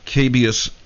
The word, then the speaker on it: Cabeus: Now, that crater is called Cabeus A. I think that's how you say it. C-A-B-E-U-S. Cabeus A. I think that is the name. I think that's the pronunciation.